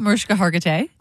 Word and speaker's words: Mariska Hargitay: Speaking of things that are hard to say, how do you say her name? – You said it right, Mariska Hargitay. – Right.